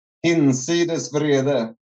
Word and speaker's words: Hinsides Vrede: Don't, don't don't don't put it through an English filter. Use if you pronounce it in a Spanish way, it's uh, easier, but you have to use the H. So it's a uh, Hinsides Vrede.